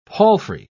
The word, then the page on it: palfrey: British English